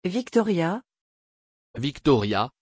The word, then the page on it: viktorija: French